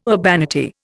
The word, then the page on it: urbanity: Indian